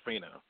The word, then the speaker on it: Fina: And could you please give me the correct pronunciation of the young lady who sang the sound of, not really sang, but who did the spoken word? – The spoken soul is what we call. You said it right. Her name is Fina